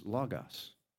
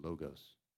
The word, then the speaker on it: logos: You could always tell the first-year seminary students because they said logos. But the proper pronunciation, just for if you want to appear slick, is logos, right? All right. Logos, that is the Greek word for word. Now, it's not word like the words I'm using to communicate with you.